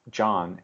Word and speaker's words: Jon: It's John, and it's, my first name is spelled J-O-N, but it's John_A_Munson.